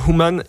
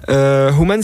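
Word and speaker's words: Whoman: Human, c'est qui? Donc ça s'écrit W-H-O-M-A-N, c'est un jeu de mots entre woman et who.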